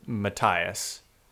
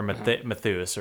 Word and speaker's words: Matthias: The very end of this book, our boy, our main character, Matthias, or Matthias, or whatever you want to say his name. I want to say Matthias. – I've been doing Matthias, but either one.